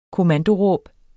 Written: kommandoråb

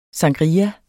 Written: sangria

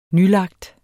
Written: nylagt

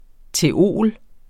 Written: theol.

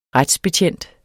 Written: retsbetjent

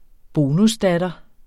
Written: bonusdatter

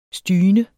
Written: styne

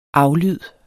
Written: aflyd

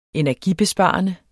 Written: energibesparende

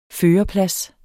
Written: førerplads

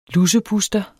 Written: lusepuster

